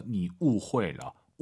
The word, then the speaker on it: 误会: 후이하지 마시고 UI 사이에 에 넣어서 你误会了. 误会了라고 예쁘게 발음하시면 좋을 것 같습니다.